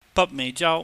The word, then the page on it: bakmeihjau: Cantonese